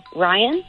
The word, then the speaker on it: Ryan: Send it to Ryan. That's R-Y-A-N. And the last name is spelled C-A-E-D-R.